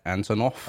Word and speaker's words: Antonoff: Jack Antonoff.